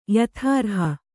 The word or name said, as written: ಯಥಾರ್ಹ